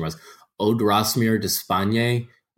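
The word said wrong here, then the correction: Odrisamer: Odrisimer Despaigne.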